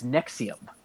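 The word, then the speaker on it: NXIVM: It's Nexium. That's how you pronounce it. Nexium. Even though the letters, if you pronounce it phonetically, it doesn't look like that, but it's pronounced Nexium.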